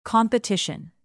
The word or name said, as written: competition